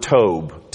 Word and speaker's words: tobe: The Hebrew word is, and this will be an easy one for you, is the word tobe, T-O-B-E, tobe, that's how you pronounce it. It wasn't spelled T-O-B-E because they didn't have any T's, O's, B's, or E's.